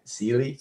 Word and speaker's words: Sealy: Sealy? – Sealy, like the mattress, but Sealy, if you see the spill, like, mine's worse.